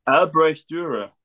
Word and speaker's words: Dürer: Now, how do you pronounce his name? Because my Australian ruins everything. – Albrecht Dürer. – Albert Dürer. – Dürer.